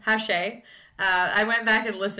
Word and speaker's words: Hache: Thank you to the listener who actually emailed us after part one dropped and let us know that Rory Hache's last name is pronounced Hache and not Hash as I originally pronounced it at the beginning of part one.